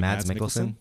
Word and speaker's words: Mads Mikkelsen: Mads Mikkelsen.